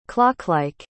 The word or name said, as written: clocklike